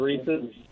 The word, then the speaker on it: Reese's: Probably Reese's. – Reese's, good. Hey, and you said it correctly, too.